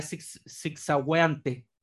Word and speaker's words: zigzagueante: Bueno, tiene G-U-E sin cremilla. Así que es un sonido No, bueno, sería zigzagueante.